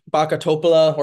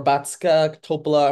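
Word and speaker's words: Bačka Topola: Not lose to Backa Topola or Batska Topola, however you want to pronounce it.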